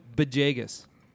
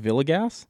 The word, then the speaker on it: Villegas: Camille Bejaygus. – What's with the B at the start of his name? – I don't know. That's how it's pronounced.